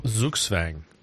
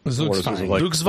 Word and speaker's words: Zugzwang: And I'm trying to get like I wonder are they going to have like a little audio Zugzwang. – Zugzwang.